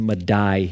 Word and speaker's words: Medai: That's the Medes and the Persians, okay? But it's pronounced Medai here as it's written.